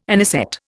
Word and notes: anisette: Indian

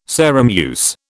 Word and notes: Sarum use: British English

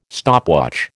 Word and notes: stopwatch: American English

